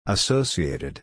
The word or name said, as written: associated